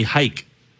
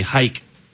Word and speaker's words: Jayk: When I lived in Spain, they call me Hike. – Well, yeah, the J for them, they pronounce like. – Because I spell my name J-A-Y-K, so they call me Hike.